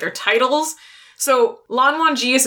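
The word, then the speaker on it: Lan Wangji: Yeah, it's pronounced Lan Wan Ji, but it is spelled like W-A-N-G-J-I.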